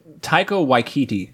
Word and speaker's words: Taika Waititi: I liked Takiti Wakata. I apologize if I'm pronouncing the name wrong. – Hawaiian Island. – He was the director. – Taika Waititi. – Is that what it is? Okay. – Yes.